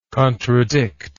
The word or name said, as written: contradictor